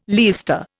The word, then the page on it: leister: Indian